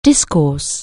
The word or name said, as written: discourse